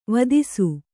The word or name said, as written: ವಧಿಸು